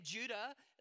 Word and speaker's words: Judah: But Jude was written uh, by a man named Judah, that's actually how you'd pronounce his name.